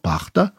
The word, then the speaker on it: пахта: Слушайте, кстати, я всю свою жизнь говорил «пахта». Всю жизнь ошибался. Ну, а сейчас вот посмотрел в словаре, ничего не поделаешь. Во всех словарях написано, что правильное ударение на первый слог – пахта.